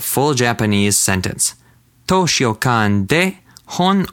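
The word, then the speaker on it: Hon (本): Hon.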